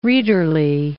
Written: readerly

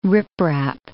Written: riprap

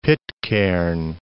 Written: Pitcairn Island

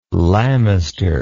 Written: lamister

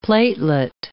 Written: platelet

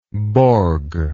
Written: Borg

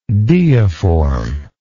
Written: deiformity

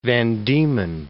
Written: Van Diemen Gulf

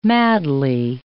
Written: madly

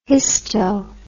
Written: histo

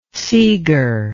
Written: Segor

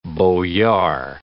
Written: boyar

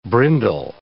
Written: brindle

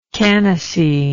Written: canace

